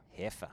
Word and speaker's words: heifer: How do you pronounce heifer. – It? – Heifer. – Heifer. – Yeah.